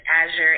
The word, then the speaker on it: Azure: Well, let me first of all say that my website is my name, so it's Azure, A-Z like zebra, U-R-E like Edward, Arthur, A-R-T-H-E-R, and that is .com.